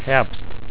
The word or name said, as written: herbst